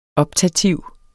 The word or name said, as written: optativ